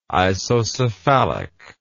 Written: isocephalic

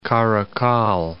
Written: Karikal

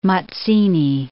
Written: Mazzini

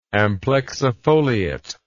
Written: amplexifoliate

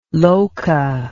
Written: loca

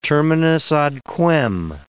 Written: terminus ad quem

